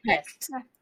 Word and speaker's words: Hecht: Hecht. Okay. – Hecht.